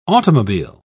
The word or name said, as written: automobile